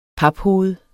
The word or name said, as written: paphoved